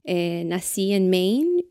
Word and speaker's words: Maine: Se llama Maine es el único estado de, una sola sílaba.